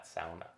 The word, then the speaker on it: sauna: And I say, oh, it's actually the Nordic sauna, more commonly pronounced that way. But then I say, or as my family would pronounce that, sauna, because that's a practice from Finland, my homeland, that I've grown up with, that for some reason, as we go through the process, I feel so de-stressed along the way.